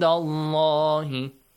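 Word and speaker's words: الله: The pronunciation of the letter Lam in Allah is heavily pronounced. And Allah.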